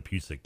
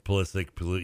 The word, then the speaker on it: Pulisic: The Pusick guy, Pulisic.